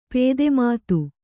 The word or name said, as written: ಪೇದೆಮಾತು